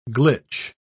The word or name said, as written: glitch